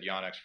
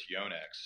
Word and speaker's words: Yonex: I said Yonex forever. It's Yonex. So Yonex, excuse me.